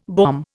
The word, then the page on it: bomb: Indian